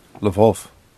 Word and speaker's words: Lwów: I believe that that's pronounced Lwów, because I believe that is a Polish town.